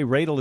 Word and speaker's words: Radel: Trey Radel is in our studio. That's spelled R-A-D-E-L. It's pronounced like dreidel, dreidel, dreidel.